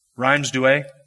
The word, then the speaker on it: Rheims-Douai: Now, just in case I pronounced that wrong, how would you say that? Rheims-Douai? Rheims. Did I get Douai right? Okay, Rheims-Douai.